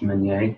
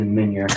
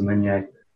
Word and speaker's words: Minier: So it's a French last name, actually. In French, it's pronounced Minier. – Can I say it like that? Minier? – Sure. – I like that. But it's been Americanized to Minier. But yeah, like three generations ago, it was Minier.